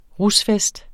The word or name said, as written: rusfest